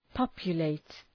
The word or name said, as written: populate